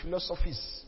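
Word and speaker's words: philosophes: He was leader of a group known as the Philosophers, but the way it's pronounced is the Philosophies, because it's a philosopher and then P-H-E-S. That's actually the French word for philosopher, but we more known with that French name, Philosophies.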